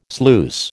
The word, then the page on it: sluice: American English